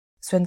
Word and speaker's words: Xuan: Et le compte s'appelle Swan Callen. voilà, vraiment mon prénom et mon nom tout simplement partout. Alors il y a une petite subtilité puisque voilà, ça s'écrit X U A N.